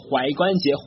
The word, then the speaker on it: 踝: Ankle，第一个原因呢是一个非常饱满的这个夸张的梅花音 ankle，它表示踝关节，这个字是念踝字啊，我小时候呢会念成裸关节，OK，赤裸，有点像赤裸裸的裸，裸关节，好，叫做踝关节，或者也可以是我们俗称的脚脖子 ankle。